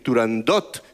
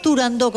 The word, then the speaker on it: Turandot: pues no había que decir Turandot, sino que, a pesar de que tuviera una T...